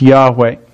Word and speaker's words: Yahweh: It's sometimes pronounced Yahweh. We're not familiar with that word. We know that at least the first syllable is Yah because of the word hallelujah.